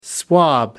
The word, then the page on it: swab: American English